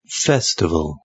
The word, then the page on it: festival: British English